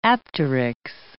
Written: apteryx

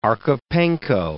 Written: Archipenko

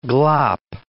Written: glop

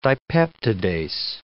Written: dipeptidase

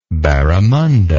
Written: barramunda

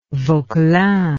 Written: Vauquelin

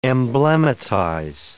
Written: emblematize

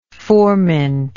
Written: furmint

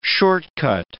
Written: shortcut

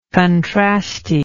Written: contrasty

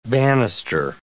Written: banister

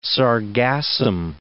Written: sargassum

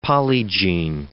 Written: polygene

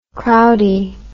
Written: crowdie

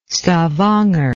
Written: stavanger

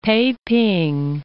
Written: Peiping

